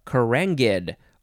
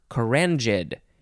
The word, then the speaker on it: carangid: Next, we have carangid, or carangid. It's spelled C-A-R-A-N-G-I-D. And you can use the j or the g sound. Either way is totally fine.